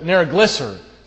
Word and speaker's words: Nergal-Sharezer: Then Nergal-Sharezer, that's a hard one to pronounce, Nergal-Sharezer, that's it, Nergal-Sharezer.